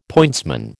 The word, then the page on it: pointsman: American English